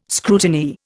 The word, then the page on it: scrutineer: Indian